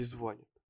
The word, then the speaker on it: звонит: Кто просто так берет и звонит или звонит? Не будем, не будем сейчас. Я очень надеюсь, что я правильно говорю. По-моему, звонит на последний слог.